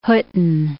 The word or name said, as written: Hutten